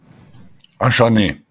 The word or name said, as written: Achâner